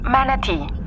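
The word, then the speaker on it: manatee: Manatí en inglés también se pronuncia manatí solo que se escribe con dos E.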